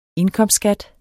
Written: indkomstskat

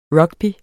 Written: rugby

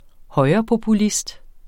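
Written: højrepopulist

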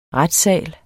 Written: retssal

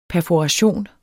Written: perforation

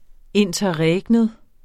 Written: interregnum

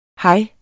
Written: hej

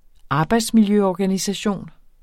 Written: arbejdsmiljøorganisation

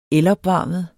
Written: elopvarmet

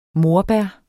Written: morbær

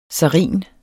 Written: SARIN

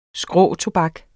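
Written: SKRÅTOBAK

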